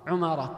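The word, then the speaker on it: عُمارة: كذلك مما يذكرون عمارة، عمارة كلها بضم العين إلا أبي بن عمارة وهو الصحابي، صحابي جليل ممن صلى القبلتين.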